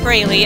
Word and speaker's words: Braley: You can find me on any social media outlet with my name, JoLynn Braley.